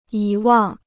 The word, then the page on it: 遗忘: yí wàng